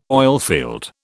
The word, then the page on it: oilfield: British English